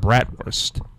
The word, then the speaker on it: bratwurst: But the third ingredient on a bratwurst, which is actually supposed to be pronounced bratwurst. – Okay. – B-R-A-T-W-U-R-S-T.